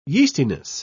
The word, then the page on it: yeastiness: British English